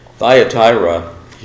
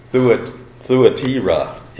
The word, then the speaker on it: Thyatira: It's actually Thuatira in Greek, but we're going to call it Thyatira because that's easy for us English speakers, and that's the way I've always read it, and it's stuck.